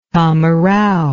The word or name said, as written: tamarau